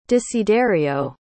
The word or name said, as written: desiderio